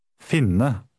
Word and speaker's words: finne: The I in word 14 has a short I sound because of the double consonant. Try 14. – Finne.